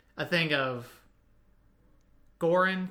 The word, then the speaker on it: Goran: It's Goran. – Goran. – Goran? – Goran Vishnik. – Goran Vish. – Goran rhymes with Lauren.